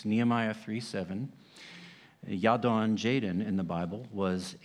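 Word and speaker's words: Jadon: Your name, it's the Hebrew is Yadon, and that's where the Celtic or English name that we call Jaden came from. So it's J-A-D-O-N in Nehemiah, but their pronunciation would be Yadon.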